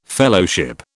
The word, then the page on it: Fellowship: British English